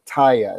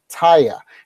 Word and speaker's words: Teye: So if you Google, the guitar company is called Taiya. It's spelled T-E-Y-E, Taiya.